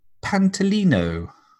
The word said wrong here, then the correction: Pantoliano: Um, you might have just noticed that I said that guy's name wrong. His actual name is Joe Pantoliano.